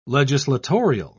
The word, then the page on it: legislatorial: British English